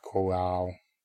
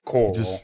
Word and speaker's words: chorale: Why do you pronounce it like that? – A chorale. – Chorale. – Oh, fine, I'll say it all redneck, like coral. – You just pronounce it very chewy. Chorale. – Coral. Fuck you. It's coral now. Shit. Now you can also be unhappy at the different wrong way I'm saying it.